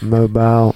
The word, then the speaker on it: Mobile: Mobile.